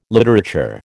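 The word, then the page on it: littérateur: American English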